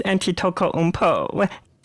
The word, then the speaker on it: Antetokounmpo: Got a nice Antetokounmpo.